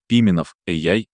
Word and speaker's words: Пименов AI: Пименов AI с ударением на «и» и без лишней паузы внутри AI.